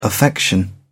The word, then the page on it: affection: English; British English